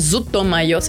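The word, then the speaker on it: ZUTOMAYO: Zuto Mayo sería la pronunciación japonesa.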